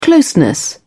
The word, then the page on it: closeness: British English